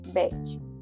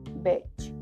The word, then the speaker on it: beige: Y de igual forma utilizamos también el color beige beige, lo pronunciamos así, pero la forma correcta es beige, beige.